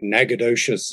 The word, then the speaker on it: Natchitoches: Natchitoches. Yeah. – Natchitoches. – Because I just remember this old story about the Marx Brothers when they were in that town and they were cracking jokes like Natchitoches is full of